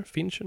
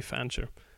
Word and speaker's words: Fancher: Fancher? Fincher? Nej. – Fancher, Fincher tror jag inte han heter. Nej, det är Fancher.